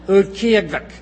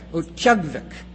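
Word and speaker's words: Utqiaġvik: Ut-ki-ug-vik. Ut-kyug-vik.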